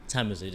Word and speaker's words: Tsamezeu: Tsamezeu, there you go.